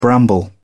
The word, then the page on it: bramble: British English